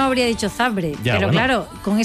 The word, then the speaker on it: Zabrze: Górnik Zabrze de Polonia 2, Mónaco 3, ganando el equipo monegasco. ¿Por qué sabes que es Zabrze? ¿Por qué no? ¿Por qué pronuncias Zabrze? – Porque es así.